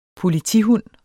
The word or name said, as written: politihund